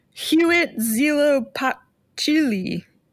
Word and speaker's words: Huitzilopochtli: Huitzilopochtli. That's how that's pronounced.